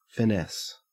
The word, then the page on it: finesse: Australian English